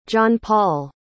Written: johnpaul

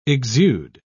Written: exude

